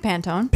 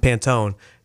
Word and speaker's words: Pantone: Pantone? – That says powder blue. – But what? How do you say the company that does it? – I say Pantone. – Pantone.